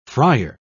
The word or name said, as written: friar